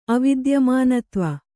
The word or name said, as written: ಅವಿದ್ಯಮಾನತ್ವ